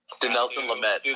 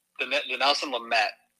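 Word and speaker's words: Dinelson Lamet: Denison Lemay, I don't know how you say his name, just because I don't like to talk about the guy because he sucks. He just got DFA'd by the Rockies. – Denelson Lamet. – Denelson Lamet. Thank you.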